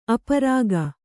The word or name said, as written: ಅಪರಾಗ